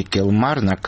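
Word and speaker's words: Kilmarnock: установленный в шотландском городе Килмарнок в середине 90-х, будет демонтирован. Конец цитаты. Подчеркну, кстати, для тех, кто не знает, что ударение в названии этого города надо ставить на втором слове.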